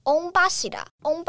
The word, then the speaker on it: 御柱: 这次的祭典叫做玉柱祭，玉柱就是念作 on 巴西啦，on 巴西啦，就是玉柱的意思。